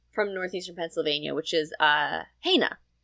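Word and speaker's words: Hainna: They just started saying ain't it so fast that it just became Haina.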